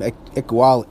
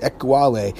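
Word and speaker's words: Ekuale: Byron Cowart, Daniel Ukiali, I can't even pronounce his name. Ekwali, there it is, Ekwali.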